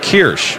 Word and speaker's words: Kirsch: By the way, Brandon's last name is spelled K-I-R-S-C-H, but we were informed this morning when we got here it is pronounced Kirsch, like quiche. – Yeah, K-E-E-R. Kirsch.